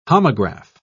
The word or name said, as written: homograph